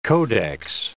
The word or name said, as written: codex